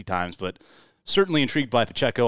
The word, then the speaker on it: Pacheco: I botched his name. It's Pacheco.